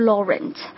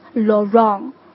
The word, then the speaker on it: Laurent: OK，最后一个单词的发音不是 Laurent， it's Laurent。